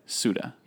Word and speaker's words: Suta: It's S-U-T-A. – S-U-T-A. – Yeah. – And you pronounce it? – Well, you pronounce it Suda. – Suda. Yeah. Dino Suda is about to embark on one of our sketches here.